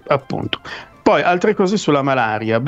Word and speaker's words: malaria: C'era, esatto, il termine malaria penso che è stato coniato a Venezia. – Quindi si dice con quell'accento lì. – Malaria, sì, e si scriveva mal apostrofo aria, certo.